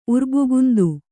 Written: ಉರ್ಬುಗುಂದು

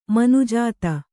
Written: ಮನುಜತೆ

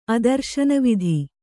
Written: ಅದರ್ಶನವಿಧಿ

